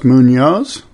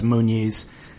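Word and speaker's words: Muñoz: It is how you say his name, and you're not the only one who mispronounces it. I heard one of the commentators, the you know, professional broadcasters, mispronouncing it as Muniz, which is Alejo's last name.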